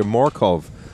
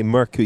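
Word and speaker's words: Mørkøv: Well, he told me last year, Morkov, Michael Morkov. If you're going to say Merku, you also have to use the Danish pronunciation of Michael, which is not Michael, something different.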